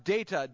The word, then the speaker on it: data: All right, not data as in Star Trek. All right, data, D long A, T A, it's an old Persian word.